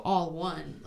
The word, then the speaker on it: Alwun: It's spelled weird. It's spelled like ALWUN, so All One, like, but we're all one community.